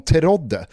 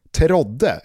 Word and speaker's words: Terodde: t e r o d d -E. Terodde, eller Terodde.